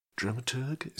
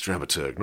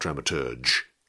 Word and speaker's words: dramaturg: Dramaturg? Is that how you say it? To be honest, I've never quite known. I think it's dramaturg, not dramaturge. No, I think it must be dramaturg.